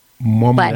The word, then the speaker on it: Mwamwa: It's pronounced Mwamwa. – Mwamwa? – That's right. Mwamwa.